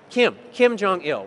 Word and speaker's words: Kim Jong-il: What happened there is when the fuel stopped running, the gas stopped running, you know, that was propping up Jim Jong-il, is that how you pronounce it? Kim Jong-il, right.